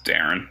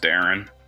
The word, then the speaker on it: Deron: Is it A, Darren Williams or Deron Williams? I've never been clear on how to say his name. – It's Darren. It's just Darren.